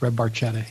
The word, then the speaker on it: Barchetta: Red Barchetta, if I'm saying that correctly. – Yeah, it is. Red Barchetta, yeah.